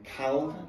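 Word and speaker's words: Calne: Yeah and I finished in Cal, as the locals say it, yeah.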